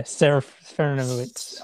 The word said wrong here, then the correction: Serafinowicz: I can hear it in my head, and it's just my mouth can't make those shapes. Peter Serafinowicz. – Serafinowicz.